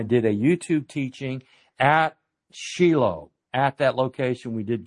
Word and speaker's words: Shiloh: I hate to tell you, folks, it's not pronounced Shiloh. In Hebrew, it's Shiloh. Shiloh sounds more euphonic, I give you that. But it's the proper Hebrew pronunciation is Shiloh.